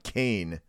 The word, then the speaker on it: Kean: Now wait till you start talking about Kane, which is spelled K-E-A-N, and Buena Vista.